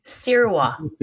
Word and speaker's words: Sirois: So how do you pronounce it? – Sirwa. – Sirwa. – Like you're gonna sear meat.